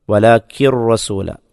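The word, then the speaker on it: വലാക്കിർ റസൂല (ولكن رسول): വലാക്കിൻ എന്നതിലെ ആ നൂനിൻ്റെ ഉച്ചാരണം, സാക്കിനായ നൂനിൻ്റെ ഉച്ചാരണം അവിടെ കാണപ്പെടുകയില്ല. ആ നൂനിനെ റായിലേക്ക് ചേർക്കുകയാണ് ചെയ്യുന്നത്, ഇദ്ഗാം ചെയ്യുക എന്ന് പറയും. അപ്പോൾ അവിടെ സാക്കിനായ നൂനിൻ്റെ ഉച്ചാരണം കാണപ്പെടില്ല. വലാക്കിർ റസൂല, വലാക്കിർ റസൂല.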